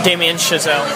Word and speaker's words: Damien Chazelle: Damien Chazelle.